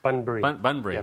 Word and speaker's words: Bunbury: He's coming all the way from, it was Burberry. Burberry, am I saying that right? – Bunbury.